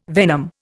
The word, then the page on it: venom: Indian